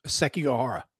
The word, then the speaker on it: Sekigahara: Sekigahara.